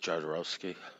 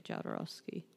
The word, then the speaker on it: Jodorowsky: You pronounce it Jodorowsky. – Yeah, you say Jodorowsky.